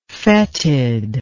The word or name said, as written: foetid